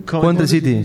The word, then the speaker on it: Coventry City: ¿Cómo se pronuncia este equipo que nunca en mi vida pronuncié? ¿Es ¿cuál? – Coventry o Coventry City. – Coventry City. Sí, efectivamente.